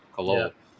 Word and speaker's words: Khalil: It's Khalil. – Right? Khalil. Yeah.